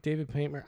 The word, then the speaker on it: Paymer: David Paint. I mean, David Paymer.